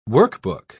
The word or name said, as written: workbook